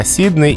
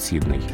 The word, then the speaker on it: Сидней: А вот э, в грамматическом словаре Залезняка э, даны два варианта Сидней и Сидней. И при варианте Сидней стоит такая помета, что это ударение а, не соответствует основному принятому в языке, но встречается в речи людей, которые профессионально связаны с этой страной, или в речи специалистов-филологов.